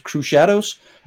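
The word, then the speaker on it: Crüxshadows: The lead singer pronounces it Crew Shadows. C-R-U-Umlaut, like my last name. But that's a heavy metal umlaut, so it doesn't actually change the sound of the U. But C-R-U-X Shadows.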